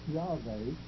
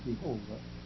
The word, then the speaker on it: Yahweh: It is Yahweh, would be probably the correct pronunciation of it. We sometimes say Jehovah, but that's an erroneous pronunciation, however. Even if it were correct, it wouldn't be Jehovah, it would be Yehovah, because the Hebrews have no hard-sounding J. Their J sounds yet like a Y in our language. So sometimes you'll find the tetragrammaton is J-H-Y-H or Y-H-Y-H-N.